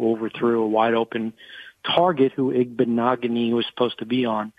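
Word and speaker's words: Igbinoghene: Noah Igbinogheni rhymes with mahogany. – Beautiful. – That's a good way to remember it.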